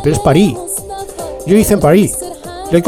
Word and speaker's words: París: Porque los franceses de París no pronuncian la S cuando dicen París, ¿no? Os lo digo por si vais allí y tal y queréis parecer un poquito de allí, no pronunciéis la S.